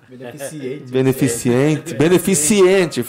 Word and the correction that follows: beneficente: Falei errado ainda. Não, é beneficente mesmo.